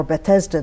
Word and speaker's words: Bethesda: But the meaning of the name Bethzatha or Bethesda, depending on how they pronounce it, is house of mercy.